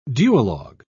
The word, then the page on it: duologue: British English